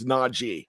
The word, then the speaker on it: Najee: I'm gonna say Najee Najee Harris, and I had to say it right because he came out and said, "Hey, it's Najee.